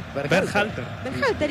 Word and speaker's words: Berhalter: ¿Cómo es el apellido? – ¿Cómo? – Dígalo usted. – Berhalter. – ¿Y qué dije yo? – No, impecable.